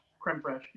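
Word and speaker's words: crème fraîche: So I got bacon, pepperoni, clams or oysters, pulled pork, quinoa, shrimp, hummus, kale, blue cheese dressing, and then cream, I don't even know how to pronounce that, cream fraiche? – Creme fraiche, yeah.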